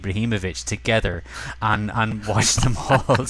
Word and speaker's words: Ibrahimovic: So I think Abrahamovic was done a couple of times, and Zoltan was sort of offered up a few times.